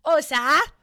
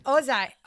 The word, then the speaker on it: Ohza: Oza. – Okay. – Oza. O-H-Z-A. Oza.